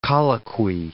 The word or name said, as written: colloquy